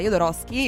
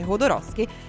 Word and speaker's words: Jodorowsky: Alejandro Jodorowsky, perché si pronuncia Jodorowsky, io per anni ho pensato che si pronunciasse Jodorowsky, ma ci sono ancora dibattito su questa questione,